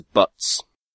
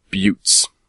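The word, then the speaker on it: butte: and I think I pronounced the particular rock formations as butts, and I've been told they are pronounced buttes.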